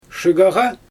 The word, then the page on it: ʃɯɯɣaɣa: Xyzyl